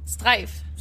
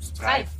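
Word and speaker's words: Strejf: It's S-T-R-E-J-F. – Yeah, but how do you pronounce it? – Sorry. Streif. – Streif. – Yeah. There we go.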